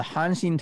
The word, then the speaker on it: Hanshin: So the Tigers are the Hanshin Tigers, and Hanshin Tigers.